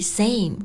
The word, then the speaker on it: same: 我们在读这句话的时候呢，要注意 same 这个单词的发音，中间是发双元音 a 的这个音。